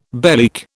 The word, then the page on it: bellyache: British English